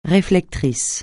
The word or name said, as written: réflecteur